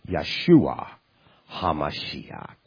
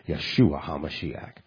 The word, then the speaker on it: Yeshua HaMashiach: He is, in the Yiddish, or Hebrew, Yeshua HaMashiach. That's how it's pronounced. Yeshua HaMashiach.